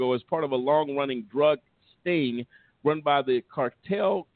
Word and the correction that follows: Carteret: County Sheriff's Office. I think I pronounced that right, Carteret County Sheriff's Office.